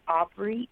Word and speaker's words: Aubry: My name is Divine, and it's spelled D-I-V-I-N-E, Aubrey, A-U-B-R-Y. My website is divineblessings.one. And that's D-I-V-I-N-E, blessings, B-L-E-S-S-I-N-G-S dot O-N-E.